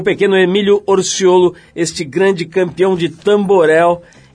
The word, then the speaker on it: Orciollo: Orciolo, Orciolo, caspita.